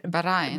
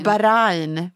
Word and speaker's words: Bahrain: nu kan inte jag uttala det här namnet en gång, eller landet, Bahrain. – Bahrain, precis. och Israel, som jag förstod det.